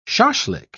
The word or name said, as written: shashlik